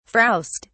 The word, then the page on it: frowst: British English